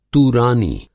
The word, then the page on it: तूरानी: Hindi